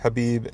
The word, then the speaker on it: Khabib: The Khabib you don't pronounce the K, Khabib and Conor fight from Saturday night.